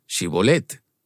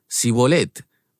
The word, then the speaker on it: Shibolet: Si respondían que no, les pedían que dijeran Shibolet. Y si el fugitivo decía Sibolet, porque no podían pronunciar esa palabra correctamente, le echaban mano y lo degollaban junto a los vados del Jordán.